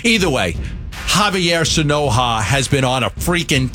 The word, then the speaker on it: Sanoja: Javier Sanoja. How do you pronounce it? – Sanoja was right, but Javier.